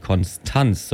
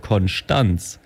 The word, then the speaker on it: Konstanz: Ich muss da gleich mal reingrätschen, das ist wieder die absolut falsche Aussprache. Das heißt ja gar nicht Konstanz, sondern in Konstanz sagt man Konstanz.